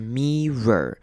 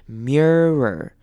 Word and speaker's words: mirror: 接下来后半段 mirror their body language， mirror 这个就是大家很容易有一些些微的差错的地方，这边我们不要念成 mirror，不用念成 me 再念 r 的声音，而是要把 mir 的声音连在一起 mirror，也就是念成两个声音，第一个声音是 mirror，后面就再念 mirror their body language。